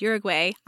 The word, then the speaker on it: Uruguay: This is an epic novel set in Uruguay, which is Uruguay. I had no idea how to pronounce it till I listened to this book.